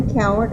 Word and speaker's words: Cowart: I'm Wanda Coward Ebersol. – And how do you spell that for the page? – C-O-W-A-R-T and E-B-E-R-S-O-L-E.